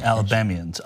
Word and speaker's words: Alabamians: These are Trump supporting Alabamans that, did I say that right? Alabamans? – Yeah. Alabamians. – Alabamians, yes. – I would have accepted the way she said it. – Well, you probably can accept, but they say Alabamian.